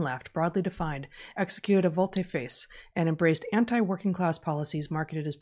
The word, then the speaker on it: volte-face: A volte-face.